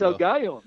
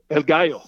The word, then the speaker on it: El Gallo: It's El Gallo. That's how you say it. You guys were talking about that earlier. – Yeah. – That's a fighting rooster in Spanish. – El Gallo? – El Gallo.